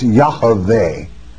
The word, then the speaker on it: Yahweh: It's Yahweh. To pronounce it correctly, Y-A-H, very small A sound, V-E-H. And the vit is locked in Esther, and it cannot be W, all right?